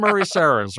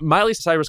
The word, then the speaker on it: Miley Cyrus: And by the way, Mariah Carey, how do you not pronounce Miley Cyrus correctly? What is happening there? She said Murray Cyrus or something.